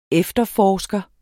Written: efterforsker

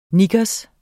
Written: knickers

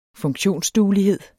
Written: funktionsduelighed

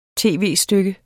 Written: tv-stykke

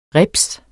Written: Ribset

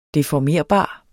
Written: deformerbar

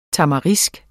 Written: Tamarix

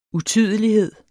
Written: utydelighed

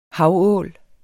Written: havål

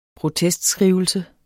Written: protestskrivelse

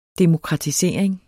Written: demokratisering

